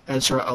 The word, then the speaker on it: Ezra: If you go to Yaspace, my name is spelled EZRAELIJAH, Ezra Elijah.